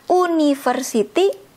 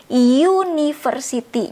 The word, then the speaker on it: university: Karena ketika kita baca ini bukan university tapi university. Jadi ada Y-nya di depannya. Bukan walaupun ditulisnya university, kita bacanya university. Jadi university karena ada Y ini ketika kita baca maka ini jadi e ya walaupun tulisannya seperti ini karena kita bacanya university. University, universitas.